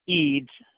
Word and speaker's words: Eads: Leroy, yes, L-E-E-R-O-Y, Eads, E-A-D, as in dog, S as in Sam.